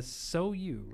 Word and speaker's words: Sou: So you, right? – Yes. – Pronounce that one. – They gave me the pronunciation on that one. It's spelled S-O-U, but it's pronounced so you.